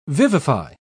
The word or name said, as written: vivify